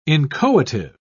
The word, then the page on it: inchoative: British English